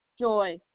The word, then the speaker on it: Joy: Okay, you said S-A-N-D? – F-O-R-D. – So, Sanford? – Yes. – Okay, and the first name? – Joy. – You spell that? – J-O-Y.